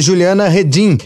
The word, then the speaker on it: Juliana Redin: A gente fala agora aqui no Mundioca com a professora Juliana Redin, especialista em migrações internacionais e refúgio. Ela é cátedra Sérgio Vieira de Mello da Universidade Federal de Santa Maria. Falei certo, professora? – Sim, correto.